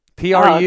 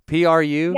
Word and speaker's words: Proulx: No, dang it. PRU. – This is a good list. – I know. PRU. – Yeah. Her name is spelled very weird. It's PROULX.